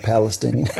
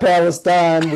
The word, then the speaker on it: Palestine: It's genuinely called Palestine. It's not Palestine.